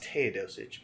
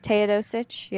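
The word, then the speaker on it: Teodosić: I think it's pronounced Teodosic, but Teodosic, yeah.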